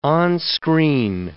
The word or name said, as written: on-screen